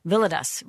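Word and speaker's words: Villodas: Rich Valoda. I'm so psyched out that I've already pronounced his name wrong. Villadas. Rich Villadas joins me today to talk about his book, Good and Beautiful and Kind, Becoming Whole in a Fractured World.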